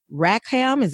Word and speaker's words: Rackham: When you were a grad student at the University of Michigan, Rackham, am I saying that right? – Yes.